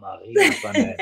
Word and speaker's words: Marie Antoinette: Ah, oh, Marie Antoinette. It sounds so French. So one of the most famous examples in history, no? So she said something like, let them eat cake. – People say, Marie Antoinette said that.